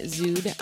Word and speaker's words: Szud: So check out my SoundCloud, Stephanie Zood on SoundCloud. – You want to spell that one? – Yes, S-Z-U-D is how you spell the last name.